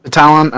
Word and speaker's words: Batalon: Batalon, okay.